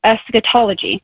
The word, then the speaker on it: eschatology: If I'm pronouncing it right, it's like eschatology.